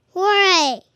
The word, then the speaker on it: Jorge: How do you say George in Spanish? – Jorge.